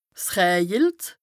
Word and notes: 's rëjelt: Bas-Rhin; Strasbourg

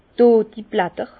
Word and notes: d' dodi Blätter: Bas-Rhin; Herrlisheim

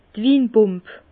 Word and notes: d' Winpump: Bas Rhin; Herrlisheim

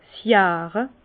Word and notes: 's Jare: Bas-Rhin; Herrlisheim

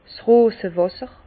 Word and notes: 's Rosewàsser: Bas Rhin; Herrlisheim

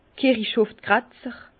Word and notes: Kirichhofgratzer: Bas Rhin; Herrlisheim

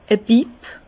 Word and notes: e Bipp: Bas-Rhin; Herrlisheim

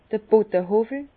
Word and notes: d'r Bodehowwel: Bas-Rhin; Herrlisheim

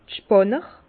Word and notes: d'r Spànner: Bas-Rhin; Herrlisheim

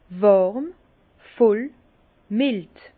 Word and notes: wàrm, voll, mild: Bas-Rhin; Herrlisheim

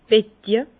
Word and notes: Betje: Bas Rhin; Herrlisheim

